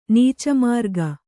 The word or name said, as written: ನೀಚಮಾರ್ಗ